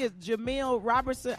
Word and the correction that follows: Roberson: I don't want to mispronounce his last name. But I think Roberson. – Roberson. I think that's what it is, Carla.